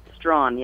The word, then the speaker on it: Straughan: Straughan, yes. – See, I just guessed because of Vaughn.